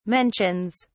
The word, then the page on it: mentions: British English